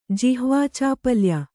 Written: ಜಿಹ್ವಾಚಾಪಲ್ಯ

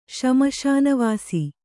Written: ಶ್ಮಶಾನವಾಸಿ

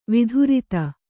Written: ವಿಧುರಿತ

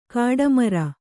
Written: ಗಡಿಮೀರು

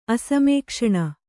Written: ಅಸಮೇಕ್ಷಣ

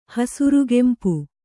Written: ಹಸುರುಗೆಂಪು

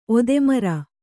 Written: ಒದೆಮರ

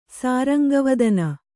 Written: ಸಾರಂಗವದನ

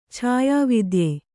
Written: ಛಾಯಾವಿದ್ಯೆ

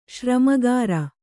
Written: ಶ್ರಮಗಾರ

